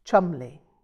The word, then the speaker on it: Cholmondeley: Penelope had rather an intimidating surname. Cholmondeley, pronounced Chumley.